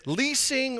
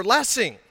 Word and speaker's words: Lessing: Is it Leasing or Lessing? I often respond, it's Blessing without the B, but I might be wrong.